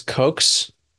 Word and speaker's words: Koch: How did you say that, by the way? Did I nail it? – I believe it's Koch's postulates. Robert Koch. I don't know. He's German, I believe, so I'm not sure what the correct pronunciation is.